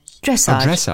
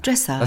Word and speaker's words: Dressage: Dressage. Also erste Silbe. – Vorne. – Dressage.